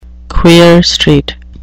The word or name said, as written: queer street